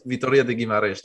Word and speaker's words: Vitória de Guimarães: Vitória de Guimarães.